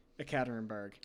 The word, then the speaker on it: Ekaterinburg: E-K-A-T-E-R-I-N-B-U-R-G. Ekaterinburg.